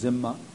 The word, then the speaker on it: Dhimma: There was also, to accommodate this diversity, the Muslims developed the system that came to be known as the Dhimma, D-H-I-M-M-A, in some languages pronounced Zimma.